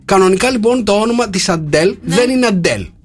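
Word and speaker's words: Adele: Λοιπόν, είπε ότι κανονικά το Α δεν προφέρεται Α, αλλά προφέρεται Ο. – Από πότε. – Ο και το Ο πρέπει να είναι πάρα πολύ χαλαρό το Ο. Οπότε είναι Ούντελ.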